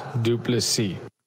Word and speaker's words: du Plessis: Dricus still knocks du Plessis. – Du Plessis. – Du Plessis. So we emphasize the last syllable, and it is Dricus.